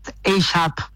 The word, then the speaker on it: AgeUp: Está bien pronunciado, ¿no? ¿Es así? AGEUP. – En realidad es en inglés, AGEUP.